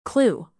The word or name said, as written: clue